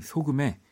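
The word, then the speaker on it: SOGUMM: 음, 저는 바로 소금이라고 하는 아티스트예요. 음, 스펠링을 보면 S O G U M M을 씁니다. 이 소금에 바로 이 큰 꿈이라고 하는 노래를 네, 골랐어요.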